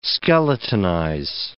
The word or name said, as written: skeletonize